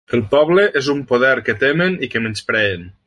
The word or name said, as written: menyspreen